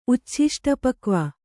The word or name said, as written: ಉಚ್ಛಿಷ್ಟಪಕ್ವ